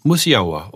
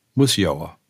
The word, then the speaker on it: Musiała: Und dann müsste ich den eigentlich ganz anders aussprechen, denn dieses, dieses L ist eigentlich ein Uhr, Wie, äh, wie, ungefähr im Englischen muss well oder water. – Ja, mhm. genau. Und dann muss ich ihn Musiała aussprechen. Musiała. – Hm. – Das wäre die echte polnische Aussprache.